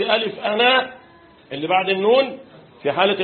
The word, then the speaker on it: أنا: الالف اللي بعد النون يا جماعه لها ايه؟ صفر مستدير ولا قوسين مفتوحين؟ طيب صفر مستدير ده معناه ايه؟ تكتب ولا تنطق حال الوصل، شرط لابد، انما في حال الوقف تنطق وتكتب. وانا لو هنوقف ماشي كده، لكن لو هنوصل هنقول وانا اعلمه، مش كده؟ يبقى الالف بتاعت انا اللي بعد النون ده ايه؟ تنطق وقفا لا وصلا.